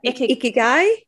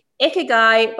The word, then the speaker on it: Ikigai: Ikigai? – Ikigai by Hector Garcia.